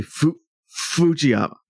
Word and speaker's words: Fujiyama: While we're on the boss, this is important because I consulted with somebody who is fluent in Japanese on how to pronounce his name, and it's actually Fujiyama.